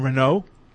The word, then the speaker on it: Renault: Do you pronounce the LT in Renault? No, of course not.